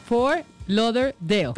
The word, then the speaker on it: Fort Lauderdale: Anuncia Avianca hoy Bogotá, ayúdeme acá a pronunciar Fort Lauderdale. – Yo es que Fort Lauderdale. – Bueno, ¿cómo es que es? – Fort Lauderdale.